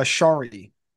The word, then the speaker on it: Scharre: Is it Scharre? Is that how you pronounce it? Is it Scharre? – It's actually Shari.